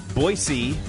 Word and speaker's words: Boise: Please pronounce it Boise. Apparently, they'll tell you that if you go.